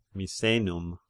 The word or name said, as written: Misenum